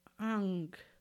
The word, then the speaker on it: Hong: El sonido al inhalar que tu cuerpo produce es el sonido ang, que se escribe HONG y pronuncias UNG, ang.